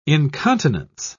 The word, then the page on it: incontinence: British English